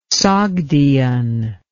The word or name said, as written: Sogdian